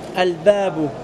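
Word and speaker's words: Al-babu (الباب): Quand je dis Al-Bab là, faites attention, est-ce que vous entendez l'alif al-lam? Al-babu. C'est vrai ou non? Je le prononce et en plus il sera écrit.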